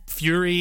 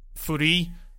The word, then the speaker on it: Furi: The whole thing is that it's not Fury. It's like the way that Japanese people would say free, because. And like, he actually played a bunch of, he played like an example of, you know, the Japanese language thing saying furi,